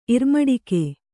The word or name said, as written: ಇರ್ಮಡಿಕೆ